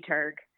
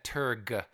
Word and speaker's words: turg: You just have to enunciate that Turg. – That hard G. – Right, hard G.